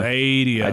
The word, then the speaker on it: Stadia: You threw an R in there. – Stadia.